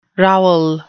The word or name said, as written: rowel